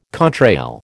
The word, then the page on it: contrail: American English